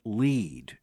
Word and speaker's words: lede: the opening paragraph of a news story are referred to as the lead. Interestingly, it is spelled L-E-D-E, but it is the paragraph that begins the article.